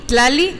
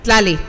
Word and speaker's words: Tlali: Peguali es P-E-U-A-L-I. Tlali, como suena, T-L-A-L-I. Eh, pronúncialo bien, Nantli. Peguali. – Peguali. Tlali, Tlali.